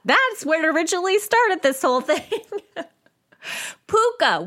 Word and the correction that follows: Pukka: which is actually Pukka. I definitely was saying it wrong, which I had the sneaking suspicion I was saying it wrong, but I just carried on because I think Puka sounds funny. but Pukka T. I have since learned that that is the proper pronunciation thanks to the lovely Gemma Hardy on Facebook.